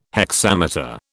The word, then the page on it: hexameter: British English